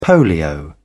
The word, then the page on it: polio: British English